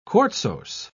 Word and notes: quartzose: British English

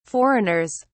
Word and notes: foreigners: British English

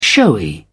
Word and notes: showy: British English

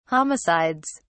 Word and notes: homicides: British English

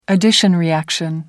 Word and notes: addition reaction: American English

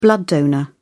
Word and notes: blood donor: British English

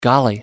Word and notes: golly: American English

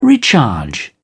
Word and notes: recharge: British English

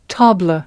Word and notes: tabla: American English

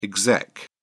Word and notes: exec: British English

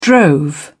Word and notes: drove: British English

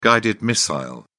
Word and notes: guided missile: British English

